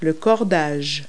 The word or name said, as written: string